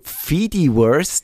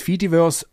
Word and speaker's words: Fediverse: Genau, äh, ihr merkt, wir plaudern da uns ein bisschen warm in der Hauptsendung geht es dann um ein Fedi, sagst du Fediverse, tust du das Englisch aussprechen? Ich sag's beides, Fediverse und Fediverse, je nachdem.